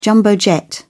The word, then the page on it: jumbo jet: British English